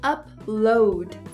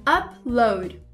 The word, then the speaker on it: upload: Só que a forma correta mesmo de pronunciar é upload. Você viu que o up fica igual, mas o load fica diferente do que load. Pratica comigo. Upload.